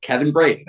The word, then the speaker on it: Kevin Brady: As it is, as it's pronounced with, you know, in the Irish, it's Crivino Brady. So it's just Kevin Brady. That's all it is.